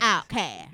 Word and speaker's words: OutKast: I mean, the mighty, mighty Outkast. – Mighty. – Pronounce Outkast. – Outkast. – Yeah. – Don't even say the T.